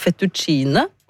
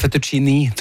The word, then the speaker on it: fettuccine: Jeg tror det fettuccini, tror jeg det uttales faktisk. Men ellers var det kjempebra. – Ja, men det var skrevet med E, skjønner, for jeg tenkte også I.